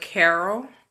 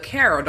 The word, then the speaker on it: Carroll: So what you would type in is Breaking the Spell and then put my name, Alayla Carroll. Or you can just type in my name, Alayla Carroll. Don't forget the apostrophe. And Carroll is spelled with two R's and two L's.